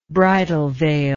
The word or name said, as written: bridal veil